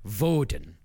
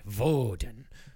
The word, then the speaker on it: Woden: Except Woden. It's pronounced Woden. – Oh. – Yeah, but in the German way, Woden.